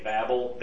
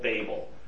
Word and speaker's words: Babel: Because just sounding it out in the Hebrew letters, it can be a long or a short A. Babel, Babel. It can be either one.